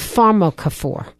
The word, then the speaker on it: pharmacophore: Science and computer technology, and you use a word that we hadn't seen used before called pharmacophore. – Yes. – Is that the way you pronounce it? – Pharmacophore, yes.